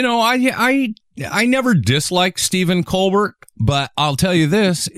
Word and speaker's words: Colbert: And Stephen Colbert is great. Do you know Colbert is not how we pronounce, that's not actually how his last name is pronounced.